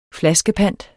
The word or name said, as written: flaskepant